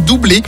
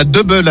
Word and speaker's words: Double: En 1983, à deux, ils deviennent doublés. Et il faut bien le prononcer doublé à la française et pas double à l'anglaise, un point de détail qui tenait beaucoup aux intéressés.